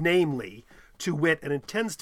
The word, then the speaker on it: viz: How do you pronounce V-I-Z if you're read out loud to someone? How do you pronounce V-I-Z when you see it? – I would say viz. – I have looked this up, and I've seen people say you should just pronounce it namely.